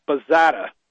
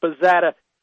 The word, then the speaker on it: Bazata: Former OSS operative Douglas Bazzata. That's spelled B-A-Z-A-T-A.